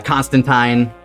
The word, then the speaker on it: Constantine: And Constantine shows up as well for a brief moment. In the comics, it's pronounced Tyne. They do a little rhyme to show it off, so...